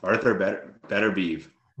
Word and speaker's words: Beterbiev: We got how do you pronounce his last name? Smith is fighting Beaverev. – Arthur Beterbiev. – Beterbiev. Thank you.